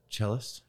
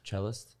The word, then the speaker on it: cellist: Celloist. – And they're... – How do you say that? – Cellist. – Cellist. – Yeah, I think that's what it would be.